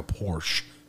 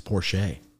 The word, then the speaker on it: Porsche: Yeah, I thought Porsche was the brand, and people said, I drive a Porsche. – Yeah. – But it's Porsche.